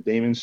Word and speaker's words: daemons: So I try to pronounce it daemons just so that people can understand it's different.